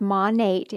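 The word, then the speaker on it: Monat: So it's pronounced Monate if you're curious.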